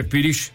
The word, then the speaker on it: pirix: Y ¿cómo se escribe? – Pirix. – Pirix. – O sea, Pirish. Se dice Pirish, es una palabra muy hermosa.